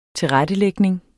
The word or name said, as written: tilrettelægning